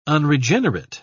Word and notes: unregenerate: British English

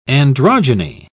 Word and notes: androgyny: British English

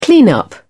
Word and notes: clean up: British English

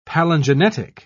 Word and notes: palingenetic: British English

